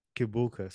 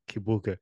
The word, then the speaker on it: Kibuka: When I say my name, Barney Kibuka, so I say like Kibuka, but like back home they say Chibuka, so like that.